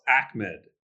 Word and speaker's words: Ahmed: But, you know, do you think Ahmed's now worked himself into a role here, even when Gaskin gets back? – It's actually pronounced Ahmed. So, Ahmed.